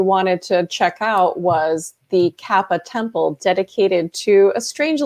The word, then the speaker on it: Kappa: So you went to Japan and you were investigating the Koopa. Is that how you pronounce it? – The Kappa. – Kappa. All right.